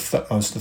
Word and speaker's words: Thutmose: I speak of Thutmose III, and Thutmose is spelled T-H-U-T-M-O-S-E, Thutmose.